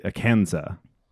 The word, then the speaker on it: Akansa: Arkansas comes from the Quapaw tribe, but the French explorers called it Akansa. – You called it, dude. – Yes. The French don't pronounce their S's. Yeah. So making this plural, it would be Akansa.